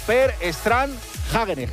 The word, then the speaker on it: Per Strand Hagenes: La ha ganado un noruego, Per Strand Hagenes, que esto se pronuncia así literal, sí, porque he llamado...